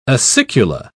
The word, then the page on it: acicula: British English